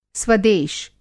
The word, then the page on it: स्वदेश: Hindi